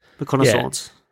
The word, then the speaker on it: McConaughey: Because obviously McConaughey. How do you say that? – McConaughey.